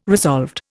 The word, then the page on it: resolved: Indian